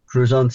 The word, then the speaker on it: Crouzon: So you have this, was it again, Crouzon? Did I say that right? – Crouzon syndrome. Yep.